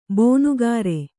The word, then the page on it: ಬೊನುಗಾರೆ: Kannada; bonugāre